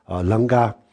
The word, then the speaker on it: 楞伽: 然后呢这个大乘楞伽经楞伽就是它的准确的读音就是楞伽。